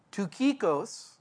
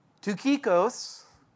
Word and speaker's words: Tychicus: So in verse 7, Tychicus, don't laugh. I actually looked that one up. And I bet most of you wouldn't look at that word and say it like that. Tychicus, a beloved brother.